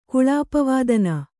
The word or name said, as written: ಕುಳಾಪವಾದನ